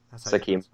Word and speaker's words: Sakima: The next song, the third song off the EP, is Nobody But You featuring Sakima. Sakima? – Yes. Sakima.